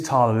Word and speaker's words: Tala: You are the founder of, make sure I get this right, but Tala Thrive? It is Tala. There's not some pronunciation that me being British and kind of overlooking. – No. You're good.